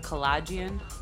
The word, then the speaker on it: Kalajian: My name is Andrea Kalajian, K-A-L-A-J-I-A-N. I know a lot of people have a hard time pronouncing the last name. – I've been saying collision this whole time. – Well, it's collagen. – Okay, so to be honest, my grandma, she taught us how to say our name wrong. She wanted to, like, Americanize it. So she would say collagen. So we got stuck saying collagen until, like...